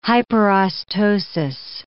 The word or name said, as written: hyperostosis